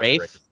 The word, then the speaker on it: Rafe: And then the antagonist Nadine and Rafi, who kind of looks like Jake Gyllenhaal, in my opinion. – I think it's Rafe. I think it's pronounced Rafe.